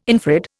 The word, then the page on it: infrared: Indian